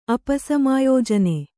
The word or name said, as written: ಅಪಸಮಾಯೋಜನೆ